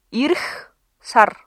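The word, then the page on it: ирэх сар: Mongolian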